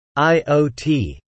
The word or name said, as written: IOT